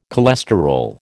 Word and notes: cholesterol: American English

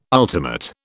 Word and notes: ultimate: British English

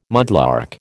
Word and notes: mudlark: American English

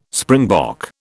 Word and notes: springbok: British English

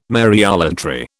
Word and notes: Mariolatry: British English

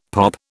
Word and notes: POP: British English

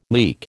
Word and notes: leek: American English

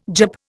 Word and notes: Jap: Indian

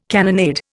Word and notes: cannonade: Indian

